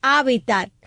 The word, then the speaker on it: hábitat: Entonces llevaría acento en la hábitat, sería lo lleva, drújula. Sí, es drújula.